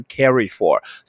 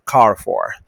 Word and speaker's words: Carrefour: 所以第四个是不要再念 carry for，不要再念，有的很很可怕，colorful，OK，好，哦，家乐福啊，colorful 没有说 OK，不是 carry for，是 car for。